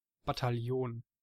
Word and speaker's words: Bataillon: Bataillon, sag's einfach auf Bataillon. – Deutsch. – Bataillon. – Ja. Sag's einfach auf Deutsch, das ist gar nicht so schwer.